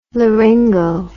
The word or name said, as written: laryngo